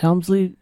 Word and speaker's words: Helmsley: We have had some marvelous conversations in the most beautiful of environments in the Helmsley building.